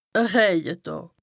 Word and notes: e Rëjedàà: Bas-Rhin; Strasbourg

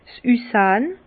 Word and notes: 's Üssahn: Bas Rhin; Herrlisheim

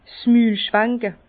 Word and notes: 's Mül schwanke: Bas Rhin; Herrlisheim

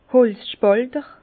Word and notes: Holzspàlter: Bas Rhin; Herrlisheim

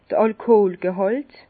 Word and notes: d'r Alkoholgehàlt: Bas Rhin; Herrlisheim

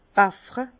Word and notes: baffre: Bas Rhin; Herrlisheim

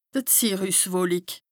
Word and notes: d' Zirrüswolik: Bas-Rhin; Strasbourg

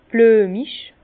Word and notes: blüemig: Bas-Rhin; Herrlisheim

